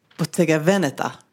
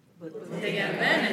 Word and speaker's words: Bottega Veneta: Kan vi säga uttalet i kör? – Ja, har ni lärt er hur man säger det? Eller har vi lärt oss? – Okej. Okay. – Ja. Bottega Veneta. – Bottega Veneta. – Ja, bra! – Superbra.